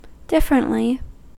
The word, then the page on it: differently: American English